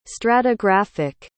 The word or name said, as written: stratigraphic